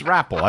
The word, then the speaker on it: rappel: I think it's rappel. I think the accent's on the first syllable.